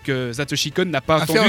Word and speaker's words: Satoshi Kon: Perfect Blue, alors de Satoshi Kon, c'est comme ça Est-ce que je le prononce bien Tu le prononces très bien.